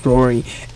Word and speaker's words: Raury: It's all Rory, R-A-U-R-Y, like Maury instead of M, spelled with an R, Rory.